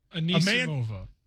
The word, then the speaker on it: Anisimova: Anisimova.